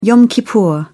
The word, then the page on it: yom kippur: English; British English